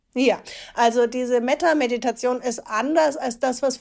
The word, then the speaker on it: Metta-Meditation: Du hast das erste Kapitel, ist die Metta-Meditation, ist das richtig ausgesprochen? – Ja.